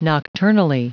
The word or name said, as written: nocturnally